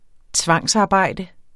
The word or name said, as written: tvangsarbejde